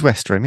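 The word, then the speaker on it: Westerham: That's the right word, isn't it? Westrum. What's the where Westrum. Is it in Kent? It is Westrum, yeah. Pronunciation, geez.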